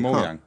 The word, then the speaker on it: Mojang: Mojang.